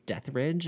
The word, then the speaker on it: Deathridge: Yes, so I am on YouTube and TikTok and Instagram, and it's just my name, Gunnar, G-U-N-N-A-R. My last name is Deathridge. It's spelled like Death E Rage.